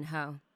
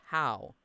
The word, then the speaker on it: Ho: Or, you know, for Vivian Ho. It's pronounced how. Like I said, Ho.